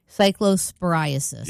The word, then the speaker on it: Cyclosporiasis: Cyclosporiasis.